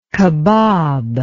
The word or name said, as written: kebab